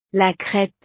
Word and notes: la crepe: frances

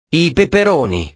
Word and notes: i peperoni: italiano

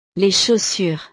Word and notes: les chaussures: frances

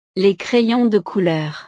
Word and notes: les crayons de couleur: frances

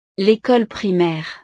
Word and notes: l'ecole primaire: frances

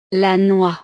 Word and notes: la noix: frances

